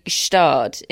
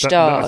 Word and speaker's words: Gstaad: Well, anyway, the Swiss one is definitely Gstaad. In fact, I think it's a sort of silent G. – Yes, Gstaad. I think that's correct.